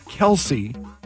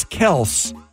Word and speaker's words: Kelce: That it's not Kelce, it's Kels.